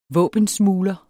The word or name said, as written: våbensmugler